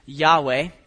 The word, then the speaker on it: Yahweh: If you're not familiar with the term Yahweh, in your Bibles, when you see Lord in all caps, that is translating a Hebrew word that we pronounce as best we can, Yahweh. And